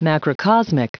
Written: macrocosmic

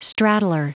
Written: straddler

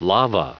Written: lava

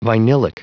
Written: vinylic

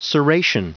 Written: serration

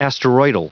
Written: asteroidal

